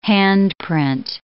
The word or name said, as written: handprint